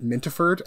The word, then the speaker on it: Mintaford: I'm trying to get stuff published right now, but at the moment just find me on Twitter at Mintaford, MYNTAFORD, a nickname that a friend gave me because they felt mint wasn't long enough, so it became Mintaford.